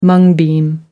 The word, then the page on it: mung bean: American English